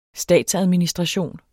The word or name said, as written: statsadministration